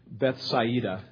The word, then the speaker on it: Bethsaida: We find in John chapter 1, verse 44, that they actually lived in Bethsaida, is how you pronounce it.